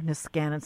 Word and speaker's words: Niskanen: He's a senior housing policy analyst at the Niskanen Center. I'm sorry for mispronouncing the center, Niskanen Center.